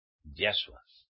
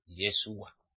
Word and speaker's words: Yahshua: Ya es Yahweh, Yahshua, uno es. ¿Entienden? No puede ser Yeshua. Como dicen los mesiánicos.